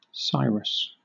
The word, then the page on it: Cyrus: /ˈsaɪɹəs/; Southern England